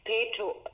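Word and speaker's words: Petow: Peto. Also Peto spricht mir's aus. Paula, Anton, Emil, Theodor, Otto, Westen. Mhm. Das spricht man nicht aus.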